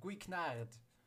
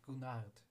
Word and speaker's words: Guignard: Aber ich habe heute gelernt auch, dass er ähm, französisch äh, angehauchte Namen im Italienischen trotzdem äh, normal, also italienisch ausgesprochen werden. Also müsste ich sagen Guinard und nicht Gunard.